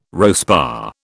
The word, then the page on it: RoSPA: British English